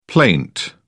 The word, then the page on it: plaint: British English